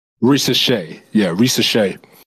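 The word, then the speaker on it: Risacher: I guess I would throw in Rishire. I don't know if I'm saying his name correctly. Rishire. – It's Reese Shea.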